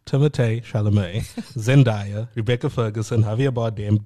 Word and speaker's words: Timothée Chalamet: It's Timothée Chalamet. – That's how you say it. – Is that how he says it? – No, that's how you're supposed to say it.